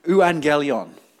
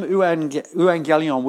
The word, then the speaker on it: euangelion: It uh, is a Greek word um, called let me pronounce, see if I can get this right, and some of you Greek scholars might be able to uh, correct me, euangelion. Is that right?